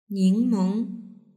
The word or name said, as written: ningmeng